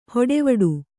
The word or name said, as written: ಹಟವಿಡಿ